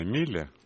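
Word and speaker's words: Miele: Соня, не могу с вами не согласиться, и мне на память приходит реклама стиральной машины, как она, Миле, правильно произносится, немецкая. Она так и звучит.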